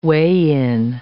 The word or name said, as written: weigh-in